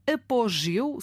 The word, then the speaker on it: apogeu: Desta vez é uma pergunta que vem através do nosso ouvinte Rui Capelo, que quer saber qual é a pronúncia da palavra apogeu. Será apogeu ou apogeu, com o O ali mais aberto?